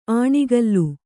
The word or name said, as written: ಆಣಿಗಲ್ಲು